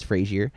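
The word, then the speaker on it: Fraser: Brandon Frazier, which apparently that is how you say his name, by the way. It's not Frazier, it's Frazier.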